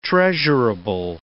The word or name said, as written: treasurable